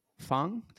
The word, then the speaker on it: Feng: Joining me today is Dr. Lana Feng.